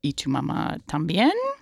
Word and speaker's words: Y Tu Mamá También: You may also know him from Y Tu Mamá También.